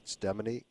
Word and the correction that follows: StemoniX: Stemony. – StemoniX, yes.